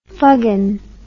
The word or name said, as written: fuggin